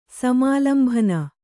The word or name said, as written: ಸಮಾಲಂಭನ